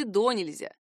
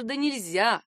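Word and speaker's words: донельзя: Как только не ставят ударение в этом слове, можно услышать и «до нельзя», и даже «да нельзя».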